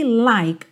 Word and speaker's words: like: De novo aqui, like, o E não é pronunciado, o K já junta no I.